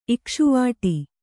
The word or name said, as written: ಇಕ್ಷುವಾಟ